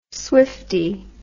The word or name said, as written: swiftie